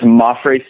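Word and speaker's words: Mapfre: Before I introduce you, how do you say the name of the stadium? – It's Mafre Stadium.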